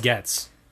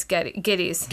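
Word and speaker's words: Gittes: Geddes. Geddes? – They kept calling him Getz. – Yeah, it's Geddes. – Geddes, it's G-I-T-T-E-S.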